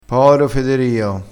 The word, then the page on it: poro: dialettale